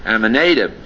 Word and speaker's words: Amminadab: Looking at that word Amminadab, here it's pronounced Amminadab.